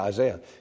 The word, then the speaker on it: Isaiah: And he says, did I say that right? Yeah, Isaiah.